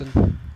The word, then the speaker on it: Guyton: Jalen Guten? Is that how you say it? Guten? Guyton? Not sure. – I'd go see, with this Guyton. – Guyton.